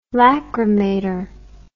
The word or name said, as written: lacrymator